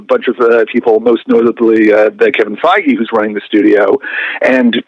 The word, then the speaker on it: Kevin Feige: Kevin Feige. – Kevin Feige. Okay. Yep. That's spelled F-E-I-G-E.